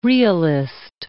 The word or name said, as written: realist